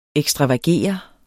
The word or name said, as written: ekstravagere